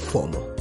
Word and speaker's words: FOMO: Este término es una sigla que en castellano podríamos pronunciarla como FOMO.